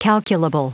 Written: calculable

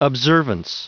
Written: observance

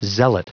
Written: zealot

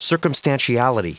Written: circumstantiality

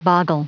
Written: boggle

